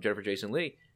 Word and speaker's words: Jennifer Jason Leigh: And the psychologist who was played by Jennifer Jason Leigh. Did I say that right? Yeah.